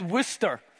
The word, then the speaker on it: Worcester: But it's pronounced Worcester.